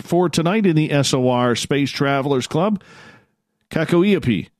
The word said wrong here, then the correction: Kakoipi: I butchered that. Kakoipi. There we go.